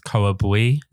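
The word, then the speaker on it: Khoa Bui: You know, he put me onto like, I think it's Coa Bui. This is the way it's pronounced, a Vietnamese guy.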